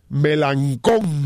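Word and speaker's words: Melancon: Melancón. – Tenga cuidado cuando usted pronuncie esa palabra. – Bueno. Por eso exagero la L.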